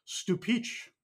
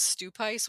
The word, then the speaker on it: Stupice: Sometimes I read it as stupice, which I know is not correct. But that's how it's spelled. It's S-T-U-P-I-C-E. – Mm-hmm. And I think you are pronouncing it correctly.